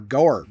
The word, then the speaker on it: gaur: Next animal, the gaur, G-A-U-R, gaur, gore. Background, gores rhyme with powers. Okay, so it's gaur.